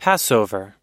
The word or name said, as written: passover